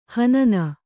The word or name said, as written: ಹಿನ್ನೆ